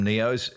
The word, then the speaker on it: NEOs: NEOs. Have I butchered that? No, you've got it perfectly.